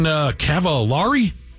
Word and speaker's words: Cavallari: Kristen Cavallari? Is that how you say it? – Cavallari? Yeah.